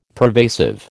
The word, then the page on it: pervasive: American English